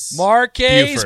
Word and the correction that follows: Marquez: When we called him that one day, he said Marcus. – Yeah. – But it's spelled Marquez. – Buford. – Marcus Buford. That is correct.